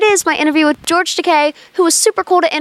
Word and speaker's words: Takei: It's pronounced Takei.